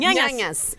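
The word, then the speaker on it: Ñañas: Como é que fala isso? – Nhanhãs. Clube Nhanhãs.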